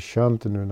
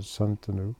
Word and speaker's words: Shantanu: And the Shantanu is Shantanu, not Santanu.